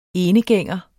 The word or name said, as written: enegænger